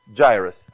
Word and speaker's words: Jairus: When, I was for Jairus, how do you say this? Jairus, thank you.